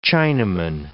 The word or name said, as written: Chinaman